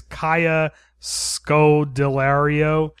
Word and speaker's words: Kaya Scodelario: And I don't know how to say her name, but the main character is Kaya Scodelario. – Oh, you got it.